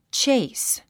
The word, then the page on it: chase: tʃéis; チェイス